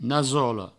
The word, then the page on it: nasola: Provençal; nasolo